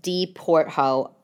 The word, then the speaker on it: D. Porthault: D Portho. The website. You spell it D, period, P-O-R-T-H-A-U-L-T. French company.